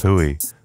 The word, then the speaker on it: Hooi: That's Y dot L. Well, not dot L, but it's pronounced Y.L. Hui, spelt H-O-O-I.